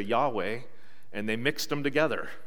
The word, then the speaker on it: Yahweh: Scholars today believe that Yahweh is the most likely pronunciation for these four consonants that we have.